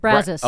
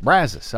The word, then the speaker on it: Brazos: The Brazos, is that how you say it? – Brazos. – Oh, you're from Texas. – Brazos. Yes, I am. – That's how you say it. Brazos.